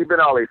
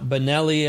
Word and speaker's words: Frankie Benali: So the late, great Frankie Benali. It's not Benelli as some people would pronounce it.